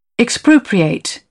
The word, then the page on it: expropriate: British English; əʊ